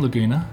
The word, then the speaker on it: Laguna: Is that how you say your last name, Laguna? – Correct.